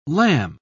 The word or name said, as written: lamb